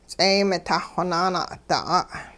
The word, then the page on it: ts'eey-mitah-xonaana'da'a': Hupa